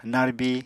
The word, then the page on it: narbi: Apatani